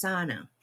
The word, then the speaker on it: Sinai: I mean, in a way, I mean, and Dr. Sinai, I called her Sinai. Is that how you pronounced it or no? – I in my head, she's Sana.